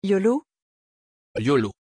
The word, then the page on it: iolo: French